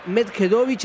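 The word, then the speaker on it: Medjedović: Y juega Joao Fonseca ante Hamad Medjedovic, el serbio, y está ganando 2-1 el brasileño.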